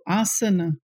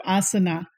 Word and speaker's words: asana: it becomes Y-O-G-A, you know, and so obviously people sound it yoga, but actual pronunciation is yoga, and then also asana instead of asana.